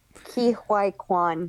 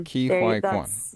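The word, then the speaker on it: Ke Huy Quan: I'm going to say Huai Quan. I don't know. I'm so sorry. – Ki Huai Quan. – Ki Huai Quan.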